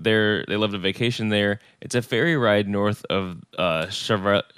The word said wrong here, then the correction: Charlevoix: I believe. – Charlevoix. – Okay, Charlevoix. I should have asked you to pronounce that one as you're a Michigan person.